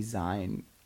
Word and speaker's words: zine: It looks like it should be pronounced Zine. – Yeah, because I forget that it comes from magazine. – Yeah, but who wants to spell it Z-E-E-N? It just looks like peen.